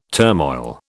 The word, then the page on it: turmoil: British English